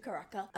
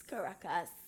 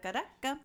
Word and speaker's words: Caracas: No, but that's the way I pronounce it. And I am going to own it. – Because you're from Barquisimeto. – Yes. – It's Caracas. – Caracas.